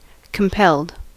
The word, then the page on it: compelled: [kəm.ˈpɛld]; American English